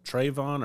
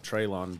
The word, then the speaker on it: Treylon: Um, is I'm pronouncing it Trayvon or Traylon, Traylon, Treylon Burks.